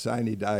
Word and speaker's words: Sine die: Murphy was speaker, but you said it's pronounced Sine Die, y'all.